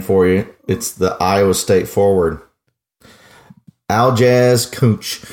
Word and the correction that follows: Kunc: Or no, it's K-U-N-C. I want to pronounce that correctly for everyone. K-U-N-C. Aljaz, A-L-J-A-Z, Aljaz Kunch.